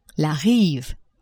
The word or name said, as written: rive